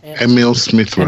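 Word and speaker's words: Emile Smith Rowe: Yeah, Emil Smith Rowe.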